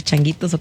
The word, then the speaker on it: changuitos: ¿O no vamos a hacer changuitos o cómo dicen por ahí? ¿Para qué? – Chulitos. – No, chulitos, sí, changuitos, sí. Chonguitos, ¿cómo se dice? Changuitos.